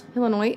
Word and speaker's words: Illinois: Illinois? Is that not how you say it? – I'm pretty sure it's Illinois. No S.